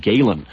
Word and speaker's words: Gehlen: And basically that centers around the role in American society and in the Cold War played by a man named Galen. His first name was Reinhard. His last name is spelled G-E-H-L-E-N.